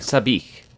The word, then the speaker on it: Sabich: Sabich.